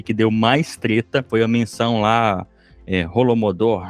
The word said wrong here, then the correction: Holodomor: acho que eu pronunciei certo, Vitor? É isso mesmo? É Holodomor.